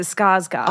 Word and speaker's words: Skarsgård: Well, they're two different names. – Skarsgård. – Yeah, I think it's Sarsgaard. – He is a Sarsgaard. – Yeah. – Alexander is a Skarsgård.